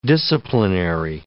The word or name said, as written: disciplinary